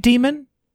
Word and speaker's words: daemon: It is pronounced demon. – I've always read it demon. – It is demon. – Okay. – Which is something that a lot of people aren't used to. The way it's spelled is it kind of looks daemon, but it's demon. – Yeah, it's the A and the E are really one letter, so it's D-A-E-M-O-N.